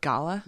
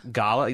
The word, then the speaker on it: gala: It's pronounced gala. – Gala, not gala. – It's gala.